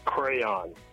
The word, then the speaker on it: crayon: But, Davis, there's only one way to say it. It's crayon.